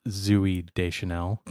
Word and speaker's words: Zooey Deschanel: I believe it's pronounced Zooey Deschanel. – Only Murders in the Building. – It has two O's in it.